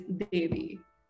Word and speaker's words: Devi: But people find Devi much easier. But then again, they're not even pronouncing it properly. – Yeah. – So I had to sit down at work one day and I was like, it's Devi, which means goddess, which means put respect.